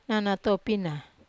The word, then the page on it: nana topina: wamesa